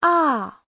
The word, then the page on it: 啊: a4